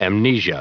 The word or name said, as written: amnesia